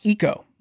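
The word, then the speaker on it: Iko: His name is pronounced Ico. We've been saying it wrong this entire time.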